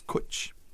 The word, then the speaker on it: cwtch: You know, it's a Welsh word, and it means like a hug or just to get close to somebody, and it's kwtch. What do you think of that? But it's spelled CWTCH.